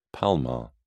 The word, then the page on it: palmar: British English